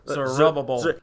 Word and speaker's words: Zerubbabel: But first of all, though, the Lord did command Zechariah to tell, how do we say that? – Zerubbabel. – Okay. – But with less rrr. Okay. – Less Zerubbabel.